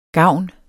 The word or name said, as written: gavn